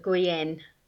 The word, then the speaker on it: Guyana: So, say when you first moved to Guyana, am I pronouncing it correctly? – Yes, the locals call it Guyenne.